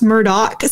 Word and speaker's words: Murdaugh: P-A-M Rebecca. Like what a douche. – Right. It's worse too. Cause I'm saying Murdaugh, but it's pronounced Murdaugh.